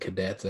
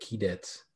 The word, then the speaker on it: Keydets: I think that the way they were pronouncing it at the selection show was like Cadets or Keydets or something like that. Oh, it wasn't Cadets. I think it's like Keydets.